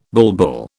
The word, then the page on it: bulbul: British English